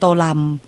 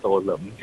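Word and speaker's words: Tô Lâm: ื่องผู้นำเวียดนามนะคะคุณโตเลิมอาจารย์คะเขามีสำเนียงภาคเหนือภาคใต้ด้วยนะคะโตเลิมโตลำครับตัวก็ออกเสียงหลากหลายมากคุณวัชรินทร์บอกว่าโตเลิมใช่ไหมครับผมขออนุญาตใช้สำเนียงไทยว่าคุณโตเลิมแล้วกันนะ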